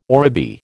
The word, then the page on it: oribi: American English